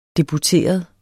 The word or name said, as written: deputeret